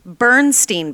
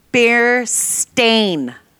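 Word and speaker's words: Berenstain: It is actually not Bernstein Bears. It is Bernstein Bears. And it is actually spelled with the S-T-A-I-N at the end.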